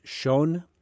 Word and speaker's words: Chão: It's that last word is spelled C-H-A-O, but it's pronounced Chão.